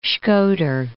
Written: Shkoder

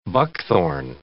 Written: buckthorn

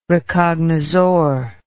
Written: recognizor

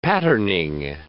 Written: patterning

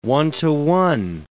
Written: one-to-one

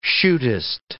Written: chutist

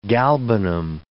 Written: galbanum

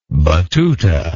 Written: battuta

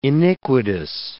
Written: iniquitous

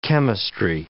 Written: chemistry

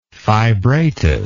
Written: fibratus

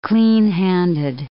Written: cleanhanded

